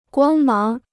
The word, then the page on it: 光芒: guāng máng